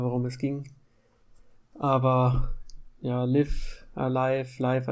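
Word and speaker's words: Live A Live: Und da würde ich auch gerne einfach nochmal zur Klarstellung, ähm, dass Live Alive tatsächlich der korrekte Titel ist, ähm, da auf die Katakana-Schreibweise hinweisen, denn an der Schreibweise, also sprich einer der Silbenschriften, die es im Japanischen gibt, kann man eigentlich immer ziemlich gut erkennen, wie ein Wort ausgesprochen wird und das wird halt Raibu Araibu geschrieben, also live alive.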